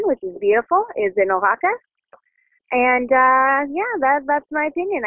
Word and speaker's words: Oaxaca: and the fact that it's pronounced Oaxaca.